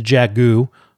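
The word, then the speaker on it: Jaghut: I mean, it's the Jagu. Silent T is established at least in my.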